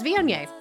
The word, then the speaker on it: Viognier: which is Viognier.